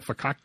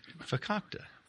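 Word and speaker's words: farkakte: And it's how do you say it? Fakakta. Fakakta. Fakakta.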